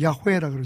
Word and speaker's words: 야훼: 보통 히브리 발음은 야훼라 그러죠. 그래서, 수목원교에서는 성경을 읽을 때, 여호와라고 안 읽고, 이제 야훼라고 읽는데, 그 야훼라고 하는 말이, 나는 스스로 있는 자다.